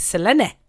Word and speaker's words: Selene: You know, like your name could be spelled, like for example, my name is spelled S-E-L-E-N-E, Celine, but it's not Celine, it's Selene or Selene.